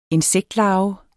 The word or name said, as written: insektlarve